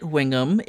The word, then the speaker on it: Weatheringham: My character's name is Violet Wingham. It's spelled Weatheringham.